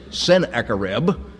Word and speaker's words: Sennacherib: We spell it S-E-N, Sennacherib, but it's the same word, sin, and it really means, may the god Sin bless the brothers.